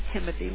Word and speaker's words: Timothy: Yes, it's Timothy,